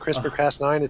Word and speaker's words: CRISPR-Cas9: CRISPR-Cas9.